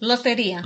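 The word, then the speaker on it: Lotería: And all, if you look up Lorita, how do you say it? – Loteria. – Loteria.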